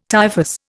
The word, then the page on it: typhus: Indian